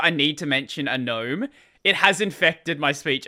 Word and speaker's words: GNOME: I've noticed that a lot more of the newer people that come to Linux base have no idea that GNOME is GNOME. Like, people are like, why are you saying it as GNOME? Yeah. It's pronounced GNOME.